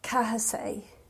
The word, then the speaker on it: Cathsaigh: And I always thought my surname was Casey, but it's actually spelt C-A-T-H-S-A-I-G-H, Kahasay.